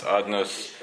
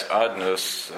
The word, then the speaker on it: Adnos: Hashem, he spoke about, it's spelled Yud Kei Vav Kei, it's pronounced Adnos.